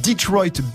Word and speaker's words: Detroit: Ça s'appelle Detroit Become Human. Ça va, je le dis bien? – Oui, j'ai essayé. On peut dire Détroit aussi.